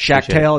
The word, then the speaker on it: Schachtel: It's Jordan Schachtel. – At Jordan Schachtel. – Yeah, yeah. – Thanks. – Schachtel, is that how you pronounce it? – Correct.